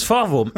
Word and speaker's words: Forvo: Es ist Forvo.